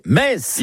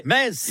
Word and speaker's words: Metz: Le meilleur moyen de mémoriser la prononciation correcte de la ville de Metz est certainement de se rendre au stade Saint-Symphorien, où vous répéterez jusqu'à plus soif. Ici, c'est Metz.